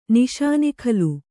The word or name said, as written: ನಿಷಾನಿಖಲು